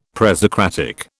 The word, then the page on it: presocratic: British English